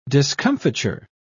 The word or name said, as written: discomfiture